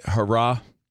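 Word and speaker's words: Herah: Elaine Thompson, how you say it? Hurrah? – I believe hurrah. – Hurrah. I hope I got it right.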